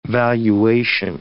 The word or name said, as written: valuation